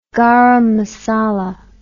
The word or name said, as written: garam masala